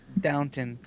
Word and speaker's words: Downton: I thought it was always Downtown Abbey, like downtown. – No, it's Downton.